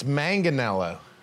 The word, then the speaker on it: Manganiello: Well, it's Manganiello. – Manga. – Yeah, I mean, Don Aaron and McKees Rocks, they do the soft G, but up in Mount Lebanon. – Not me, dude. – It's a hard G.